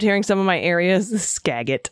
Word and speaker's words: Skagit: I almost choked on my coffee hearing AG say skagit. That lovely area is pronounced skagit? Skagit? And as to the other hilarious one, Okanagan.